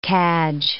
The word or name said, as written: cadge